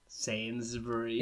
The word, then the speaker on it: Sainsbury's: Sainsbury's.